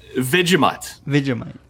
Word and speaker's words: Vegemite: Okay, what is a Vergemite? – Vegemite. – Thank you. – Vegemite. – My first Vegemite. – Wife.